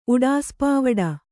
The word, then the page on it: ಉಡಾಸಪಾವಡ: kannada